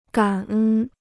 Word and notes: 感恩: gǎn ēn

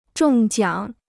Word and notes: 中奖: zhòng jiǎng

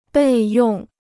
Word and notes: 备用: bèi yòng

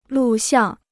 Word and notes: 录像: lù xiàng

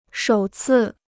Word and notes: 首次: shǒu cì

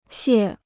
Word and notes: 屑: xiè

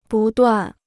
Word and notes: 不断: bù duàn

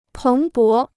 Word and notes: 蓬勃: péng bó

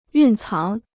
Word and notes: 蕴藏: yùn cáng

